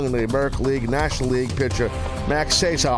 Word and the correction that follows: Scherzer: How do you say his name? Cesar? Cesar? Scherzer. Yeah, of course.